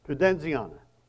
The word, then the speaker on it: Pudenziana: The name of this church, the current structure, is the Basilica di Santa Pudenza, if I said that right. My wife's going to correct me. Say it again. Pudenziana.